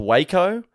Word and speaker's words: Jaco: Some of the locals did mention it was actually pronounced Waco, but I know a fucking J when I see one.